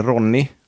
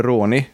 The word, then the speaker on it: Ronny: Och jag tror att jag uttalade ditt namn fel. Jag uttalade det Ronny, men det är Ronny, hörde jag.